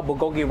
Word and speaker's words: bulgogi: Today's special at this event, we're going to showcase one of my most favorite item is the Korean-style bulgogi rice bowl.